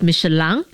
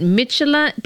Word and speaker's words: Michelin: There's the French way, which is the native way, is Michelin. – Yeah. – And then there's English and American. So apparently the English pronounce it Michelin.